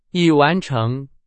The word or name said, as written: 已